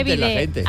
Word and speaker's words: bidet: Que no se dice bidet, se dice bidet.